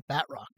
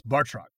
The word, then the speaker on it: Batroc: I say Batroc, but it's not. – It's Bartroc.